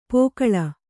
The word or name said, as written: ಪೋಕಳ